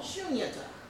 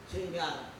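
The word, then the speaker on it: Shunyata (शून्यता): There is a synonym for this that's called shunyata. Oh, most people in Western say shunyata, but it's long u and long I at the end. Shunyata.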